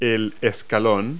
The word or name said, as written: step